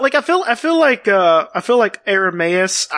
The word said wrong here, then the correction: Aramis: I said that wrong. Artemis. – Aramis. – Yeah. Aramis.